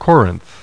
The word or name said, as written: Corinth